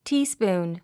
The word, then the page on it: teaspoon: tíːspùːn; ティースプーン